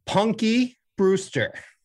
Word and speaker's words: Punky Brewster: All right, with 28.1% of the vote, Punky Brewster.